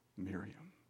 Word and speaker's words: Miriam: They started naming their daughters Miriam. That's the Hebrew pronunciation, but the English pronunciation is Mary.